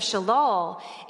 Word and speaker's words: shalal: It's spelled S-H-A-L-A-L, shalal.